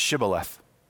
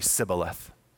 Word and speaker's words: Shibboleth: The password is Shibboleth. But the Ephraimites have a different accent and they can't pronounce it correctly. So they say Sibboleth.